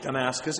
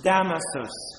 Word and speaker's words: Damasus: So he says, Damascus. It's not Damascus, it's Damasus. And it's not Damasus, it's Damasus.